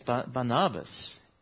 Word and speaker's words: Barnabas: Through Joseph, although he's better known as Barnabas, or as I learnt in the break after the first service, Barnabas is how it's pronounced.